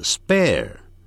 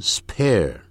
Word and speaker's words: spare: 同样的 spare, 不要念成 spare。